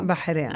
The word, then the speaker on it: Bahrain: Ja, i ett land som heter Bahrain. Eller jag kan inte säga. – Bahrain. – Ja, exakt.